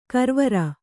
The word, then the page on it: ಗರವರ: kannada